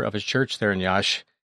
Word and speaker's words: Iași: So we got there first, and then the next morning flew immediately up to Iași is the name of the town. It's spelled I-A-S-I.